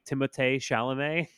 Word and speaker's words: Timothée: Denis Villeneuve, starring Timothée Chalamet, which is also how you're supposed to say his name. – Timothée.